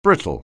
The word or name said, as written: brittle